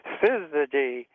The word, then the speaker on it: Syzygy: Syzygy. It's Syzygy Job, J-O-B. You spell it S-Y-Z-Y-G-Y-J-O-B.